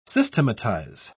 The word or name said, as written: systematize